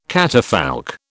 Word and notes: catafalque: British English

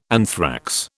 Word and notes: anthrax: British English

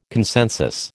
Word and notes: consensus: American English

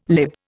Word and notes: lib: Indian

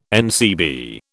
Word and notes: NCB: British English

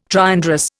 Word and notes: triandrous: Indian

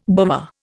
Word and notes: bovver: Indian

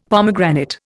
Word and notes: pomegranate: Indian

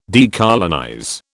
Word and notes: decolonize: British English

